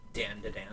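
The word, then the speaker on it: Dandadan: It's like, it's pronounced, it's spelled Dan, like D-A-N, D-A, and then Dan again. – Dan to Dan.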